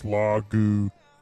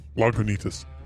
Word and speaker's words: Lagunitas: Something else I noticed on the can is they actually tell you how to pronounce it on the can. It actually says Lagunitas.